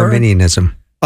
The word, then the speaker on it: Arminianism: You know, we can talk Calvinism and Arminianism. Probably didn't say that right. Should have warmed up. – That's just not Arminianism. Is that right? Did I say that? – Yeah, Arminianism.